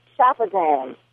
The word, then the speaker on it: Shafazand: It's Shafazan. – Shafazand. It's just Shafazan. Like it's just well. – Like it's spelled.